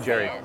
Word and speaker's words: Jarry: How you pronounce it? Hadi. Chilean, right? – Yeah. But it's like, people say Jerry. That's fine.